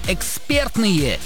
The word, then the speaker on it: экспертные: В данном прилагательном, образованном от слова «эксперт», сохраняется ударение на втором слоге, на чем сходятся абсолютно все словари, поэтому правильно говорить еще раз, как? – Экспертные.